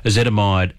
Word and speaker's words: ezetimibe: He went to a cardiologist, put him on ezetimibe and rosuvastatin. Yep. I think I said that right. – You did.